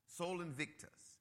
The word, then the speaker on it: Sol Invictus: See, the theory was that the Roman Empire, before it recognized Christianity, they celebrated the rebirth of soul Vic, Vic, let me pronounce this right, Victus, Invictus, Sol Invictus. It's a separated word of S O L dash V I C, I mean E I N V I C T U S.